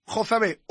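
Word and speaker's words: Jozabed: El propio interesado hoy me ha dicho personalmente que es un nombre bíblico y que la pronunciación correcta y exacta es Jozabé.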